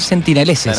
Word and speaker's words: sentineleses: Así que esos fueron los sentineleses. Lo estoy pronunciando bien, sentineleses. – Perfecto, sí. Nos van a estar acompañando.